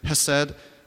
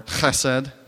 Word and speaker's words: chesed: So that word translated as mercy in Hebrew is actually a word called a word hesed. I don't know if some of you guys may have heard it, hesed or chesed, if you really want the authentic pronunciation.